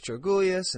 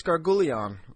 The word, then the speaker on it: Georgoulias: And I started to get kind of jealous, and I started to think, like, why are these – you know, I remember seeing Chris Georgoulias and thinking, hey, look, there's Chris Gargoulion. Because I didn't know how to pronounce his name back then.